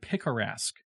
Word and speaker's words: picaresque: It's a weird book because it's the format is what's called picaresque. So this is not picturesque. This is a different word, picaresque.